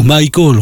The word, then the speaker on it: Michael: Michael, ou Michael, on pourrait dire, avec la prononciation appropriée,